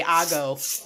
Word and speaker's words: hago: Not amo lo que hago. No. He says, escribe en inglés. It's spelled in English. A-M-O space L-O space Q-U-E space H-A-G-O. Okay, primeramente, la H es silenciosa. H is silent.